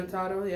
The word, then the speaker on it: Jotaro: You got Tenjin versus Jarotaro. I think that's how you say it. – Yeah, Jotaro, yeah.